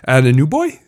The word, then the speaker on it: Anunoby: Anunoby.